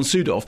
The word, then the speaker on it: Sydow: Very, very sort of acclaimed Scandinavian actor, hailed by most people as the new incarnation of Max von Sydow. I mean, which is, incidentally... – Max von who? – Sydow. We've done this before. That's how it's pronounced.